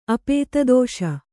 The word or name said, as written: ಅಪೇತದೋಷ